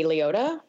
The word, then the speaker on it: Liotta: I liked seeing the descriptions of, or the different personalities of each of them on screen, you know, Laura Dern's character and Ray Liotta. Am I pronouncing it wrong? – Yeah, Liotta's you got it.